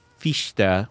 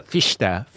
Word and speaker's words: Fichte: You know, and I think that the people who came along after him, namely Fichte, which is the correct pronunciation I learned from a German. – I didn't know that. Thank you for saying that. Yeah, Fichte.